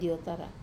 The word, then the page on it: otara: wamesa